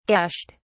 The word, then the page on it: gashed: British English